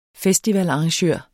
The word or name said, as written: festivalarrangør